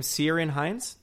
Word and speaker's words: Ciarán Hinds: Ciarán Hinds.